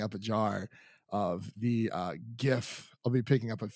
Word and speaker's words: GIF: Where my hard G boys at? – Right. I swear every single time that this happens, it's like, it's not that hard. It's GIF. It's GIF. – Graphical interchange format. Graph. Hard G. Graph.